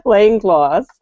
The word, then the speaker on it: Langlois: Langlois.